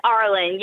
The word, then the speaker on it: Arlen: It's Arlen.